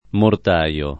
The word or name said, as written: mortaio